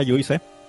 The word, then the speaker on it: Juste: Ah, y que, se supone que se pronuncia Juice.